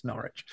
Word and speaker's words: Norwich: I just like seeing Peter get angry at our terrible pronunciations. It's Norwich.